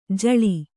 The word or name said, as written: ಜೂಳೆ